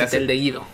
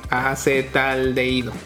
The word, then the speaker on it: acetaldehído: Acetaldehído. – ¿Acetaldehído? – ¿Qué? Acetaldehído.